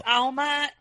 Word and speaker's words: Alma: It's Alma in Spanish for soul in English.